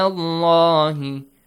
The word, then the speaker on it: اللَّهِ: إِنَّ هُدَى اللَّهِ هُوَ الْهُدَى هُدَى اللَّهِ The lam and the word Allah has to be heavily pronounced.